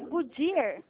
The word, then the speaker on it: Goodyear: É Goodyear.